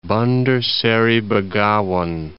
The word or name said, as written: Bandar Seri Begawan